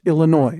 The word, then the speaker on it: Illinois: But I could not find evidence of a consistent or proper ingrained regional dialectal pronunciation of Illinois as Illinois, other than on that Surf Jan Steve Hands album. So stop it, Lauren's mom. Illinois. It's called Illinois.